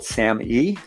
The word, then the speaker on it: SAMe: The fifth nutritional supplement I usually recommend for depression is something called SAMe. So S-A-M little e, looks like the word same, but it's pronounced SAMe.